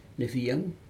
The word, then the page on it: nefiag: Aneityum